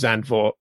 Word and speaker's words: Zandvoort: The one I always go with is Zandvoort, because that's how it's spelt, and it's the one I hear the most.